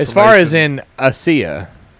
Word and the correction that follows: Asia: I'm sorry, Asia.